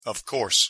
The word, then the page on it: of course: English